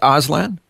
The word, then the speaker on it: Auslan: And most of the signs in Auslan are the same, but I knew a lot more putting proper sentences together and things like that, which Auslan doesn't really do. – What is Auslan?